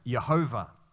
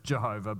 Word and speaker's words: Yahweh: Whenever you see that, that is code for God's personal name, which we pronounce as something like Yahweh or perhaps Jehovah. You might have heard it as Jehovah, but that's because we Australians don't pronounce things correctly. Jehovah, Yahweh, that's the kind of thing that we normally say.